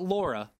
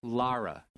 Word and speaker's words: Lara: No, not Laura. Lara.